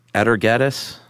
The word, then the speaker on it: Atargatis: There was one in 1000 BCE in Syria, and her name, how would you pronounce that? Atargatis? – Oh, I think you nailed it.